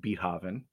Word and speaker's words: Beethoven: And Michael, I don't want to embarrass you right on the podcast, but it's pronounced Beethoven.